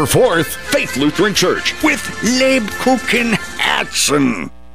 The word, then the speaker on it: Lebkuchenherzen: Lubkuchen Herzen. – The Kupenhergen Herzen. – Lubkuchen Herzen. – Nine! Lebkuchenherzen!